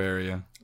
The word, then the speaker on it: Terraria: Is that how you pronounce it? – Yeah, Terraria.